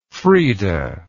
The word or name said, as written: freda